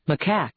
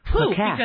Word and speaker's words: macaque: Do you want to spell it for us? – M-A-C-A-Q-U-E. – Macaque. – There we go. Good. – I think is the sound you're looking for there. – Macaque. Because I would have pronounced it the other way, and it would have sounded dirty.